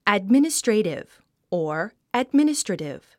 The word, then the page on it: administrative: ædmínəstrèitiv; アドミニストレイティブ